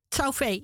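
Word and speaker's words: Cao Fei: Daar heb je bijvoorbeeld een kunstenaar gezien, Chao Fei. Zeg ik dat goed? – Chao Fei, ja, Chao Fei.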